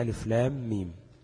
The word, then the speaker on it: الم: ألف لام ميم، ألف لا تمد، اللام تمد 6 حركات، وآخر كلمة لام حرف الميم، ثم أول ميم حرف الميم أيضا، يعني لام تنطق لام ألف ميم، والميم تنطق ميم ياء ميم. فهيلتقي حرف الميم اللي هو في آخر كلمة لام مع حرف الميم في أول كلمة ميم، تمام؟ فهيبقى مدغم، يعني هتبقى لام ميم، تمام؟